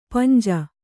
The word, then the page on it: ಪಾಂಜ: kannada